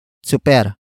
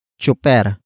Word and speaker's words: tsuper: Super is spelled as T-S-U-P-E-R. It means a driver, the destination of the telling activity, and the focus of the sentence. Some Tagalog speakers pronounce it as chuper.